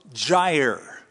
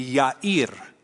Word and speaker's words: Jair: After him, so after those 23 years of adjudication, after him arose Jair. That's how we usually pronounce it. That is a mispronunciation. The Hebrew pronunciation would be Yair.